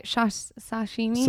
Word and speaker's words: sashimi: Hamster racing, but we need to get into the readers' questions because we got to go get some sashimi. How do you say it? Sashimi. – Sashimi. – Oh yeah.